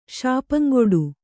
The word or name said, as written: ಶಾಪಂಗೊಡು